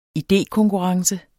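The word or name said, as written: idékonkurrence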